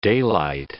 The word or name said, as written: daylight